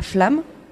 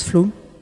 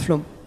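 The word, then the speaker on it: Flåm: Pour aller de Oslo, la capitale, à Flamme, ça se prononce Flom, il faut prendre le train jusqu'à Myrdal, puis le Flamsbana jusqu'à Flamme, ça se prononce Flom.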